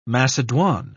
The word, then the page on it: macedoine: British English